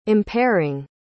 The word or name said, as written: impairing